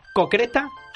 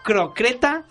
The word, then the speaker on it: croqueta: Ramiro, ¿cómo se dice? ¿Cocreta, croqueta o croqueta? – Croqueta. – Croqueta. ¿Eso es correcto? – Es correcto.